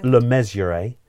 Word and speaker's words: Le Mesurier: No, I've said that wrong, haven't I? – I never know how to say it. John, Le Mesurier? – Oh, that's good. That sounds good. John Le Mesurier, who played the role of Sergeant Wilson in Dad's Army.